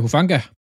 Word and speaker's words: Hufanga: Uh, Hufanga.